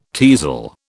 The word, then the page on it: teasel: British English